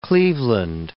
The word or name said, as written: Cleveland